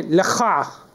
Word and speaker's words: La Croix: I say La Croix, like the French might say it.